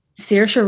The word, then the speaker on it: Saoirse: um, but uh, where do we stand on this uh, Sorcy Ronan girl? We okay with her? – Saoirse Ronan. – Is that how you say it? – Yeah.